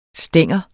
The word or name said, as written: stang